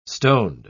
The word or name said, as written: stoned